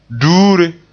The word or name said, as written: duure